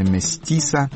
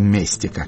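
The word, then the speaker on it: Mestiça: Спасибо, кстати, радиослушателям, указали они мне на то, что читается это именно «Местиса», а не «Местика», как я бы сказал, со своим поверхностным знанием португальского языка.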